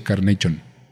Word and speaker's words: Carnation: Al que le gusta la leche Carnation. Sí, así se pronuncia.